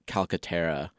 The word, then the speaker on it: Calcaterra: But yeah, so over on NBC Sports, Craig Calcaterra... wrote about how... – That's good pronunciation, man.